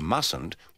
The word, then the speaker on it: mustn't: In these sentences, remember to pronounce the U of must with the schwa and the U of mustn't with the sound of the U in cut.